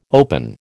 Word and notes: open: American English

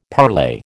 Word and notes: parlay: American English

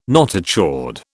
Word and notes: notochord: British English

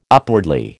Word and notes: upwardly: American English

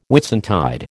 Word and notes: Whitsuntide: American English

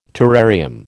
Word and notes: terrarium: American English

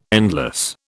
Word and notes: endless: British English

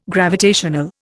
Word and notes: gravitational: Indian

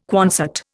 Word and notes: Quonset: Indian

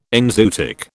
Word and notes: enzootic: British English

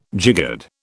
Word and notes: jiggered: British English

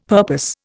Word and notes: purpose: Indian

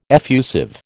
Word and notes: effusive: American English

